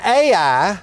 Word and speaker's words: Ai: And Ai, spelled in this case of the Old Testament, H-A-I, but pronounced like the two letters Ai, that is an area and town that means ruin.